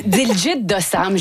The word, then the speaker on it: Diljit Dosanjh: Et on va commencer cette fois-ci avec le chanteur indien Diljit Dosanjh. – Oui, ben c'est comme ça que je le prononce. Moi aussi. Diljit Dosanjh, je ne sais pas si vous l'avez croisé ou entendu.